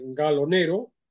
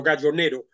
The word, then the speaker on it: Gallo Nero: Pronuncio la italiana porque la novela tiene un origen, digo, la editorial es italiana, la dueña de la editorial es italiana, aunque también hay quien lo pronuncia en castellano, Gallo Nero.